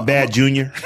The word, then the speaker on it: BADJR: I believe it is Badger. It is B-A-D-J-R. So I think that is pronounced Badger. – That sounds reasonable. – Or it could be Bad Junior.